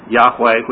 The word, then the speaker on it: Yahweh: Yahweh, which is the pronunciation of the four-letter name for God that was given in Hebrew, is gracious.